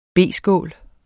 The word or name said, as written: B-skål